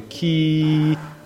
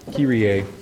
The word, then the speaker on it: Kyrie: It is called the Kyrie, and there it's phonetically spelled out for you. Kyrie. Kyrie. Yeah, Kyrie. Kyrie means Lord. It is a Greek word, and also Latin.